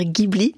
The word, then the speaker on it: Ghibli: Les studios de Ghibli, alors peut-être d'ailleurs précisons que la prononciation, on entend souvent dire Ghibli, or la prononciation exacte, c'est bien Ghibli, ça provient de l'italien qui provient, je crois, de l'arabe libyen pour désigner un vent, hein, c'est ça? – Absolument, c'est ça.